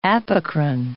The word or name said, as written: apocrine